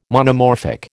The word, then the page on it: Monomorphic: American English